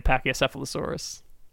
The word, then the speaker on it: Pachycephalosaurus: The Pachycephalosaurus. – Yeah. – I love me a Pachycephalosaurus.